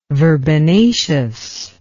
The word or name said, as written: verbenaceous